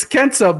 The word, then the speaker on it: Kenta: Is it Kenta or Kanta? Because I want to pronounce it correctly. – I think it's Kenta. – It's Kenta, but it's also Hideo Itami.